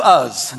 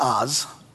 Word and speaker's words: Uz: It looks like Job, J-O-B, just pronounced Job. Chapter 1. In the land of Uz, not Oz, but in the land of Uz, there lived a man whose name was Job.